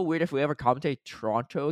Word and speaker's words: Toronto: The last T isn't pronounced. Oh, it's funny that this is like the follow up comment. Isn't pronounced in Toronto. Toronto.